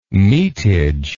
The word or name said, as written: metage